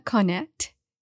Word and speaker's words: Konet: We are today, I have a very special guest that I'm really excited about, Emma Connett.